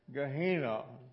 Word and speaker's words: Gehenna: And from the New Testament, we have the Greek word Gehenna. If that is not pronounced correctly, it is spelled G-E-H-E-N-N-A.